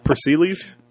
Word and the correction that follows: Pericles: Pericles. – Pericles.